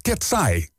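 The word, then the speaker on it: KATSEYE: Want je schrijft K-A-T-S-E, Griekse I-E. En dat spreek je uit als Ketsai.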